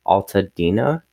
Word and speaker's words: Altadena: A model of the Real House resides in Altadena, California. Am I saying that right? – Yeah, yeah.